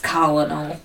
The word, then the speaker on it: Colonel: It's pronounced Colonel.